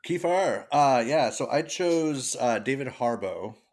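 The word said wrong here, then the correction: Harbour: or is that how you pronounce it? I can never say his name. – Harbor.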